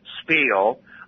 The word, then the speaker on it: spiel: S-P-I-E-L, spiel. I've also heard it pronounced spiel. I guess you can have a tiny bit of variation there in the pronunciation.